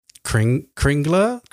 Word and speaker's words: Kringla: Like King La Crush. Is that how you say it? King La Crush, King La Crush. – Kringla, bakery in Epcot.